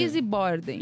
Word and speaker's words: Lizzie Borden: Lizzie Borden.